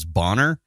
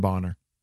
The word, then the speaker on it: Bonar: I've always pronounced it as Bonar. – Yeah. So some people do. – I think you could go either way. – You can. Yeah. I've heard Bonar and Bonar.